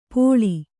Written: ಪೋಳಿ

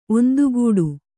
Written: ಒಂದುಗೂಡು

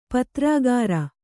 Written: ಪತ್ರಾಗಾರ

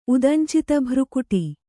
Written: ಉದಂಚಿತಭ್ರುಕುಟಿ

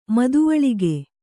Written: ಮದುವಳಿಗ

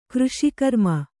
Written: ಕೃಷಿಕರ್ಮ

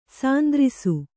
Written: ಶಾಂತರಸ

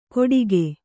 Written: ಕೊಟಿಗೆ

